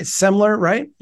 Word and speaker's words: Semler: Semler, right? – Yep.